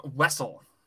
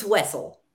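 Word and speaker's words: Wesell: So we are now like countless to, so the history behind this is there is no canon pronunciation to this character's name, but it's Wessel. You're right. It's Wessel.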